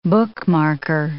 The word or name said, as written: bookmarker